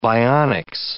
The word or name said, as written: bionics